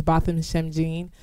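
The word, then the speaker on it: Botham Shem Jean: His name, I know I butchered it earlier, it's Botham Shemjean.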